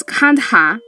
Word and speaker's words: Kandha: So, a small hill-dwelling tribe lies the Kond, locally pronounced as Kandha, which I'll definitely call them the Kandha.